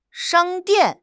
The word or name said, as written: shang1 dian4